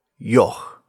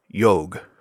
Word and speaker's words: yogh: with the letter Yog, or Yog, spelled Y-O-G-H. The pronunciation of the name varies depending on who you ask, and the sound the letter itself makes is similarly varied.